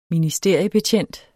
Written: ministeriebetjent

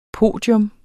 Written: podium

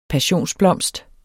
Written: PASSIONSBLOMSTER

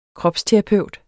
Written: kropsterapeut

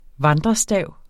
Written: VANDRESTAVENES

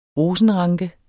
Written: rosenranke

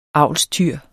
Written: avlstyr

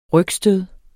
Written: rygstød

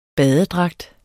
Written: badedragt

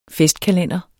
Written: festkalender

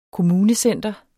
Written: kommunecenter

